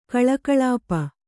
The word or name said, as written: ಕಲಾಕಲಾಪ